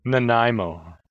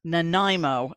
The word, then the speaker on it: Nanaimo: nanaimo nanaimo